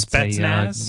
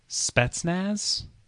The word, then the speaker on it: Spetsnaz: Sorry, am I pronouncing that right? Spinners. – No, I think that's Spetsnaz? A game of chance. – Spetsnaz.